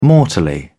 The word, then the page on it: mortally: British English